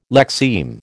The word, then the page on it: lexeme: American English